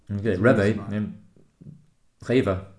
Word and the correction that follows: REWE: Is that Reve? Reve. What do you mean by Reve? – Reva? – The shop? – Yeah, the shop. – Oh, yeah, Reva. Yeah. – Reva? Have I been saying it wrong for 8 months? – R-E-W-E. – Yeah, that's yeah. – it. – Yeah, Reva. – Okay. – Sounds a bit like Raver.